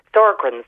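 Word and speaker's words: Sjögren's: It's the, I'm probably pronouncing it wrong, it's the one with the dry mouth and dry eyes. Oh, yes, yes. Um, Sjogren's.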